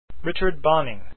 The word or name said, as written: Bonynge